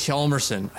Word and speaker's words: Hjalmarsson: but it's Niklas Hjalmarsson.